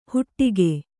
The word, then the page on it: ಹುಟ್ಟಿಗೆ: huṭṭige